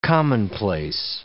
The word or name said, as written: commonplace